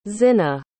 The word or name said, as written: zihna